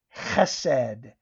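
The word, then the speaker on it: chesed (חֶסֶד): It's spelled H-E-S-E-D, but in Hebrew it's pronounced chesed, and it is steadfast love, mercy, covenant loyalty, devotion, faithfulness, and favor.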